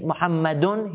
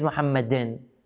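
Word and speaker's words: محمدٌ: So he said, instead of saying Muhammadun, he says Muhammadin.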